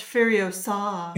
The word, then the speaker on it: Furiosa: It's pronounced Furiosa.